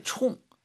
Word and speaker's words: 冲: 蒜味道, 아, 마늘 맛은, 아, 이게 太 총, 세요. 총이 이제 이게 일성으로 발음할 때 있고 사성 발음할 때 있는데 사성, 총, 셉니다.